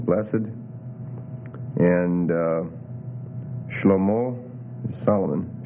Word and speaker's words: Shlomo: Shlomo or Shlomo. When I learned it in Hebrew, it was with a silent shwa there, but there's some debate about how they pronounce some of these. So Shlomo or Shlomo.